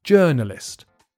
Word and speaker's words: journalist: I think this comes from a French word as well, so the vowels in the first syllable are not written in quite the same way that you pronounce it. You just say journalist.